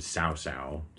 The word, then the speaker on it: Cao Cao: But so one of the most powerful characters in all of the Romance games is a guy named Cao Cao. C-A-O-C-A-O is how that's spelled. But Cao Cao has an ability in the game where he can passively influence the desires that other nations have, so okay.